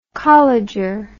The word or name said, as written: colleger